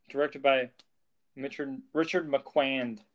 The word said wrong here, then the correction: Marquand: I think that's how you pronounce his name. Richard Marquand.